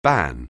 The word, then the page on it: ban: anglais